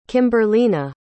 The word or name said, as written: kimberlina